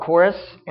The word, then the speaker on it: charis: The Greek word that is most often used in the New Testament for grace, it's spelled C-H-A-R-I-S, and I'm going to call it chorus because that was the closest pronunciation I could get down.